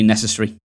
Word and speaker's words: necessary: But according to, I don't think it's quite RP, but according to an older guide, it is an Americanism to pronounce the ery. So it would be necessary. – Yeah. – And that goes all the way through all of the ARY words,